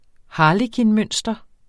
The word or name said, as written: harlekinmønster